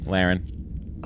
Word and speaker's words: Laren: The future of Roe Lauren. No spoilers. – Laren. – Laren. Really? – Roe Laren, yeah. – How do you spell it? – L-A-R-E-N.